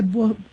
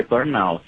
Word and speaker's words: burnout: Burnout seria uma pronúncia mais afrancesada.